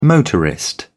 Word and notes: motorist: British English

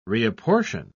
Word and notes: reapportion: British English

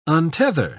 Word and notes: untether: British English

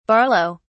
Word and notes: barlow: British English

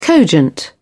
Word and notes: cogent: British English

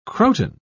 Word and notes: croton: British English

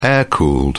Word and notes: air-cooled: British English

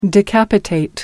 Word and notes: decapitate: American English

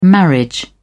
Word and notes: marriage: British English

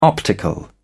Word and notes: optical: British English

